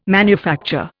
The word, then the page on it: manufacture: Indian